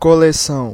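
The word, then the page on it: coleção: BR; Brazil; IPA: /ko.le.ˈsɐ̃w̃/; Portuguese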